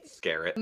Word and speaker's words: Skerritt: Top Gun is a movie from 1986 starring Tom Cruise, Val Kilmer, Kelly McGillis, Anthony Edwards, Tom Skerritt, am I saying that right? – Skerritt.